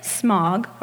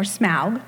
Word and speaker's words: Smaug: And at some point, a dragon by the name of Smog, or Smaug, depending how you pronounce it, comes in and takes over their territory.